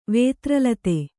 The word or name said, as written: ವೇತ್ರಲತೆ